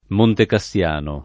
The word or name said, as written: Montecassiano